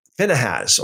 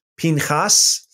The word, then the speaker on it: Pinchas: Then the children of Israel sent Phinehas, or the Hebrew pronunciation would be Pinchas,